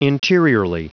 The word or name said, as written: interiorly